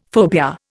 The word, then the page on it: phobia: Indian